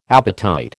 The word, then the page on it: apatite: American English